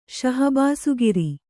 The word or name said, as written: ಶಹಬಾಸುಗಿರಿ